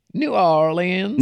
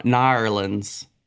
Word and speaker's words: New Orleans: New Orleans. – No, you're saying it wrong. Nawlins.